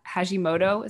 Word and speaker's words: Hashimoto: There's a question about Hashimoto. Is that, I say that right? – Yep.